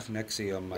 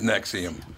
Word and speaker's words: NXIVM: Now, how do you say that again? NXIVM. How do you say that? – Nexium. – It is Nexium.